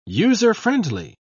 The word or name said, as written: user-friendly